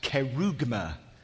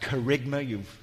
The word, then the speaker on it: kerygma: These two words, and pardon the Greek up there, but that's pronounced kerygma. Sometimes it's pronounced kerygma.